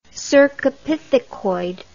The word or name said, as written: cercopithecoid